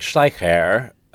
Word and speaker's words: Schleicher: Well, my family has always gone with Schleicher rhymes with biker. – Schleicher, okay. – But when I talk to like a German person, it's like Schleicher.